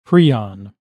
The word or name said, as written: prion